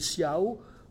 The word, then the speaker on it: ciao: Exemple à la sixième strophe ou au troisième quatrain, comme vous voulez, avec Copa, K.O., Otro, Galo, Ego et Ciao en italien, mais qui ici doit être prononcé Ciao avec un O final, puisqu'il fait assonance à la fin du vers.